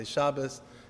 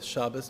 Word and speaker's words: Shabbos: Many people see Shabbos, I like to use the Ashkenazi pronunciation I'm used to growing up, it's all the same. You could say Shabbat, you could say Shabbos, whatever you like, but I'm just going to go with Shabbos, it makes it easier for me.